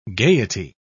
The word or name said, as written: gayety